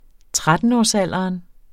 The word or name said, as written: trettenårsalderen